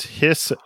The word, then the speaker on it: Hiss: My number 11 is His Golden Messenger, and His is spelled H-I-S-S. So it's not His Golden Messenger.